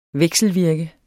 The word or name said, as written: vekselvirke